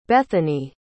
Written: Bethanee

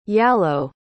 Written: yalow

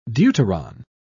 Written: deuteron

